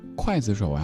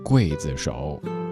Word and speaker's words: 刽子手: 很多人会念筷子手呀，可是它是刽子手。